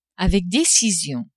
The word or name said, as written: avec decision